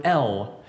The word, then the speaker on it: El: but if you were to take that Hebrew word and write it in English the way we would pronounce it, it is the word El.